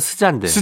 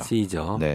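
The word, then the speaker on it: SZA: 근데 뭐쓰자라고 불러도 뭐꼭 틀린 건 아닙니다만 네. 어, 본토 발음에 본인이 이제 원하는, 원하는 발음은 네. 시저입니다. 시저.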